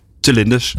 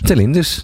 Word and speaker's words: Telindus: Ja, Telendus, of hoe spreek je het uit? Telinders. Telinders.